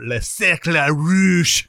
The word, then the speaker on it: Le Cercle Rouge: I watched Army of Shadows, which is really good, and The Red Circle, or Le Cercle Rouge. – Ooh. that's the exact French. – That is. That's how they say it.